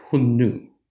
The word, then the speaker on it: Xiongnu: But the northern Huns, or the northern Xiongnu, Xiongnu is not the phonetic transcription that the Han Chinese of the 2nd century AD would have used. That's the modern Mandarin pronunciation of those same glyphs, right? So in old Chinese and early Middle Chinese, those glyphs would have sounded something like Hun Nu.